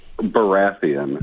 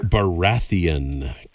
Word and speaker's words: Baratheon: Baratheon. – Baratheon.